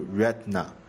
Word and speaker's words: Retina: Retina 为瑞缇娜，在这里给您纠正一下，那个字母 T 其实不发音，应该读 Retina，刚接触这个单词是专门查过它的读法啊。好的，谢谢你的这个指出，我也专门查了一下，它这个词似乎有两个读法，一个呢是 Retina 啊，